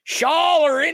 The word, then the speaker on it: Schaller: Yes, also, Schaller is what I've been told. Schaller? Is that how you say it? Schall, like y'aller. Hey, y'all in there? Schaller in there? So that's how I remember it.